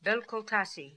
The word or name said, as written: Belcultassi